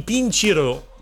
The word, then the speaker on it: Pincireux: Forse avevi ragione sulla pronuncia di Pincireux, perché Pincireux è un gruppo di carnevale di Balerna storico. – E l'hanno detto prima, eh? – Sì, sì. I Pincireux. Sì. Quindi è diverso da Punciro, come Punciro, avevamo sì.